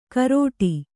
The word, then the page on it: ಕರೋಟಿ: karōṭi